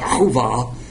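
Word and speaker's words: Hova (חוה): Isha means woman, and he renamed her Eve, which is pronounced Hova, which means the mother of all living.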